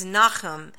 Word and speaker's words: Nahum: Today we will be reading the very short book called Nahum, or the proper pronunciation actually is from the Hebrew is Nahum.